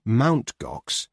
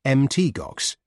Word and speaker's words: Mt. Gox: It is not pronounced Mt. Gox, but Mt. Gox, as in empty promises, as someone wryly put it.